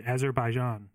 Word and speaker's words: Azerbaijan: We had Monza this week, fellas, and obviously we will be previewing Azerbaijan as well. Mm. Did I say that right? – You did. Azerbaijan.